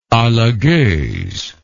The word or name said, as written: Alagoz